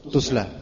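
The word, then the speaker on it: تصلى: Bacanya apa di situ? Tasla. Enggak ada harokatnya di situ ya. Kitab kuning antum ada harokatnya enggak sih? – Ada. Tusla. – Tusla. Ya bacalah tusla karena sepertinya beliau lebih condong ke tusla.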